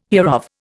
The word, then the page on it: hereof: Indian